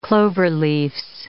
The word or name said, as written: cloverleafs